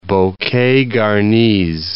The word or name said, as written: bouquets garnis